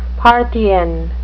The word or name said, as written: parthian